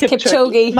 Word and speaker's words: Kipchoge: I know I keep saying that word, but I think also Eliud Kipchoge, pronounce that. – I think it's Kipchoge.